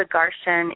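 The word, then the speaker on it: Garchen: It's called the Garshan Institute in Chino Valley, Arizona.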